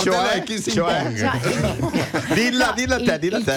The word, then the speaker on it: Xi Jinping: La pronuncia esatta è Xi Jinping. Xi Jinping, il presidente.